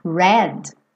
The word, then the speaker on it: read: Porque read e read, o verbo ler, tanto no presente quanto no passado, se escreve igual, mas o som muda.